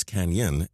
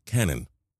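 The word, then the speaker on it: Cañon: At some point, the city dropped the tilde accent in Canyon on many of the street signs. Residents pronounce it both as Canyon and Canon.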